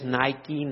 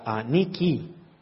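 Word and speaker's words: Niki (νίκη): Que algunos la pronuncian en inglés Nike, Nike, ¿la ves ahí? Es la palabra victoria, en el griego más bien sería la palabra Niki.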